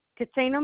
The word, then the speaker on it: Catina: call me back, Katina Morris.